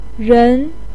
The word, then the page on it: ren: 2